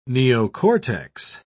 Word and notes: neocortex: British English

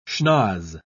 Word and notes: schnoz: British English

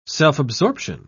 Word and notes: self-absorption: British English

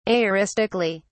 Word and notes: aoristically: British English

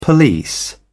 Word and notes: police: British English

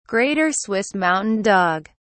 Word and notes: Greater Swiss Mountain Dog: British English

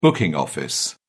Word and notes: booking office: British English

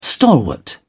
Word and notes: stalwart: British English

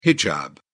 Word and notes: hijab: British English

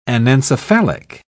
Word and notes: anencephalic: British English

